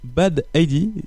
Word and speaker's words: Bad Heidi: Oui, moi, je voulais juste euh, revenir un peu sur votre nom, Bad Heidi.